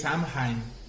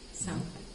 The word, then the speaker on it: Samhain: según yo es Samhain. – Samhain. Bueno, es celta. Es celta. – Mm, puede ser que sí sea una pronunciación correcta.